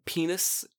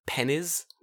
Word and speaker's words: penis: The other one is penis, which they like saying because they find it in dictionaries, but they always pronounce it as pennies.